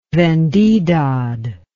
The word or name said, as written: vendidad